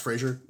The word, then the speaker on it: Fraser: Is it, oh, so it's pronounced Fraser? – Mm-hmm.